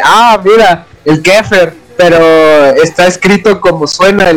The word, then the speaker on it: Xepher: Pero dentro de lo que es el templo de Satán o dentro del esoterismo, hay un término que se escribe E F-E-R, que según el templo de Satán se pronuncia como. No, se pronuncia como Kefer, ¿no? Así es, es Kefer.